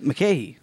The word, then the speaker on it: Mulcahy: This is directed by Russell Mulcahy. Am I saying that right? – Mulcahy. – Oh. – Or Mulcahy? – Russell Mulcahy? – Mulcahy. – Yeah.